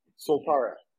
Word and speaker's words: Soltara: solterra is that how you pronounce it solterra Soltara. – solterra okay sorry if you can just explain yeah.